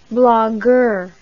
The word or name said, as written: blagueur